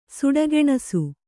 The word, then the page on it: ಸುಡಗೆಣಸು: suḍa geṇasu